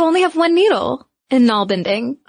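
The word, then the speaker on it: nalbinding: And I gotta tell you, side note, when I first read the word knoll bending, which, listeners, is spelled N-A-L-B-I-N-D-I-N-G, I immediately thought of narwhals. And so in my mind, when I hear knoll bending, they're using their little horns to knit.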